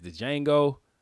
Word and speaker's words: Django: real-ass Django where the D is silent.